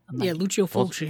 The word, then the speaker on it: Lucio Fulci: Let's see, we got Lucio Fulci, or is that how you say it? – Lucio Fulci. – Fulci, yeah.